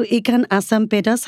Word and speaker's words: ikan asam pedas: So, ikan asam pedas, have I said it right? – Yes.